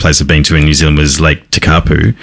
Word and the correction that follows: Tekapo: Which, Tekapo.